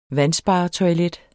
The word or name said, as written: vandsparetoilet